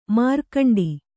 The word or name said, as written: ಮಾರ್ಗಂಟಿ